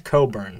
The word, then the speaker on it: Cockburn: You mentioned Bruce Cockburn. – It's pronounced Coburn.